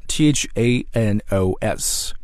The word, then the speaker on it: Thanos: 这个灭霸英文词怎么读呢？ Thanos，读着 Thanos。